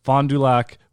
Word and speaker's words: Fond du Lac: Fond du Lac, Wisconsin.